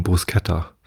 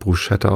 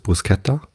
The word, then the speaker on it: Bruschetta: Und das schreibt man ja Bruschetta. Und viele sprechen es deshalb Bruschetta aus, weil SCH ist halt, ne? Ich glaube, die korrekte Aussprache ist Bruschetta. So habe ich es beim Italiener mal gehört. Ich bin halt kein Italiener, ich kann auch kein Italienisch. Aber ich glaube, das S gehört halt nicht zum CH dazu.